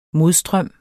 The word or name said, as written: modstrøms